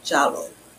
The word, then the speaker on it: Diallo: It's pronounced like with a J. Sounds like a J. Sounds like J-A-L-L-O-W, Jello, but written with the D-I-A.